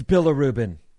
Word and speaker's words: bilirubin: Sarah is saying it correctly. It's bilirubin.